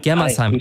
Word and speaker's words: Germersheim: Heißt das richtig, Germersheim? Habe ich es richtig gesagt? – Germersheim.